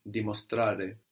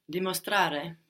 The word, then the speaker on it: dimostrare: To demonstrate in Italian is dimostrare, dimostrare. – Dimostrare. – That's spelled D-I-M-O-S-T-R-A-R-E. So actually, we have a couple of changes there from demonstrate. The N goes and the D-E of demonstrate becomes D-I in Italian.